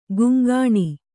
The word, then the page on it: ಕಂಕಣ: kaŋkaṇa